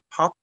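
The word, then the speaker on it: Palli: Hello, I'm Palle Thorason, although my real name is Palle Thorason. That is an Icelandic name. And Palle is my name that I'm known best for both in Icelandic and in English.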